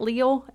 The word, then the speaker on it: Leal: Okay, so DeMarvin Leal, I think, isn't it? How you say it? Leal? L-E-A-L? – Sounds right.